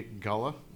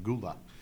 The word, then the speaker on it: Gullah: And so I think we're doing You're Saying It Wrong first, which is today, Gullah. So I don't know how many times I've heard this pronounced Gula, but it's spelled Gullah, G-U-L-L-A-H.